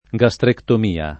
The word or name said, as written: gastrectomia